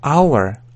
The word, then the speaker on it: hour: Обратите внимание на произношение. То есть, эта буква пишется H, но не читается hour, hour.